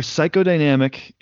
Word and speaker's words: psychodynamic: So for psychodynamic, and again, I know it's spelled P-S-Y, but the first sound is that S sound.